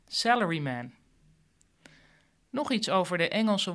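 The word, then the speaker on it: salaryman (サラリーマン): Neem het woord salaryman. Dat spreek je in het Japans uit als salaryman.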